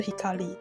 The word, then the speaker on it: 香里: 那时候我那个朋友他取叫内藤快香里，快木的快，香气的香，哦、然后香里的，所以我没有想到那个字念作 hikari。